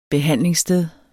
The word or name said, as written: behandlingssted